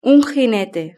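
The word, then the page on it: un jinete: Spanish (Spain)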